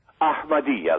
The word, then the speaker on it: Ahmadiyya: How do you pronounce it, Ahmadiyya? – Ahmadiyya. There's a little bit of an H. – Oh, I need the Semitic Ach. I'm used to the Ach. Ahmadiyya.